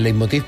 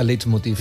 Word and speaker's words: leitmotiv: Leitmotiv, que la gente me pregunta qué diablo significa leitmotiv. Sí. Es leitmotiv en alemán, pero ya la Real Academia nos permite decir leitmotiv. ¿Ah? Tal y como se escribe, sin multa retrógrada ni nada.